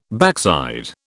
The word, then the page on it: backside: British English